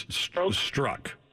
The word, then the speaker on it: Strzok: And when you look at this Peter Strzok, I believe, or it's Strzok is the way you pronounce it. It's spelled funny, but it's Strzok.